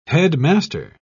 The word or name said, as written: headmaster